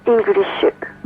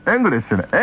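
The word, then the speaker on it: English: イングリッシュ。 – ちょっと発音が違うな。僕なんかだとね、エングリッシュね、エングリッシュなんて言うんですよ。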